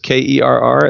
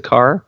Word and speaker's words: Kerr: Again, last name is just Kerr. It looks like Steve Kerr, like the coach of the Warriors, but I pronounce it Carr.